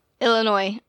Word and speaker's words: Illinois: Illinois. – Illinois? – Mm-hmm. – The S is silent. They're not going to like that. – Oh, Illinois.